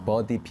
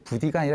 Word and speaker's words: Buddhi: 근데 그 BUDDI 스펠링은 버디라는 영어 친구란 뜻이 있는데 버디 BUDDY를 그대로 쓰면 네. 너무 이게 알고 있는 단어를 결합한 그렇죠. 게 이상해서 약간 좀 I로 썼는데 그럼 발음이 부디가 아니라 여전히 버디 같아요. 네.